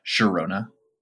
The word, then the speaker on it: Girona: I think it's the coolest new thing on the La Liga block, who we have finally figured out the correct pronunciation for, Girona, owned by none other than the Citigroup.